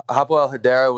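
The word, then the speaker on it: Hapoel Hadera: Hapoel Hedera? – Yeah. So Hapoel Hedera was the second team.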